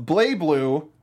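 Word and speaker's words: BlazBlue: And I don't know if anybody was expecting this, everybody, but Weisschnee is joining the cast of BlayBlue, that is actually how you pronounce it, I found out, Cross Tag Battle.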